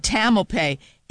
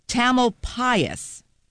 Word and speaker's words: Tamalpais: I said Tamalpais. It's Mount Tamalpais. I was not even close.